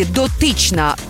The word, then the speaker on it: дотична: Наголос на другому складі «дотична» – значення таке.